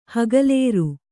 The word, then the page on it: ಹೆಗಲೇರು: Kannada